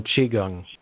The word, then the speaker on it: Qigong: And what you were talking about, my pronunciation is not going to be right. Qigong, is that right? – Oh, Qigong, yeah.